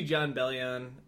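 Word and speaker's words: Jon Bellion: Don't go see John Bellion. I think that's how you say his name. – Yeah, that's right.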